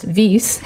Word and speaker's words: Wyss: And just for our listeners, I mispronounced it because I have to brush up on my German, but it's spelled W-I-S-S, but it's pronounced Vies, like Edelweiss. – It's actually W-Y-S-S. Yes, it's W-Y-S-S, so my apologies for that.